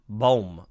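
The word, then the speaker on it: Bohm: Baum. Boehm. Alec Boehm. Boehm. Alec Boehm. Alec Boehm, not Baum.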